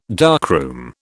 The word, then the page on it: darkroom: British English